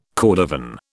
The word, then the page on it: cordovan: British English